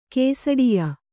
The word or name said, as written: ಕೇಸಡಿಯ